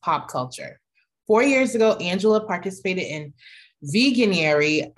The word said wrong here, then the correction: Veganuary: How do you say it? Veganuary. Veganuary. Okay.